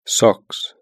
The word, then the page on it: socks: British English